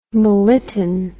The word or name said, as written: melittin